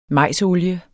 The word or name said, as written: majsolie